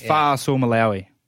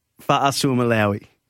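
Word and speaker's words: Fa'asuamaleaui: It's Fa'asua Malia'ui. – Fa'asua Malia'ui.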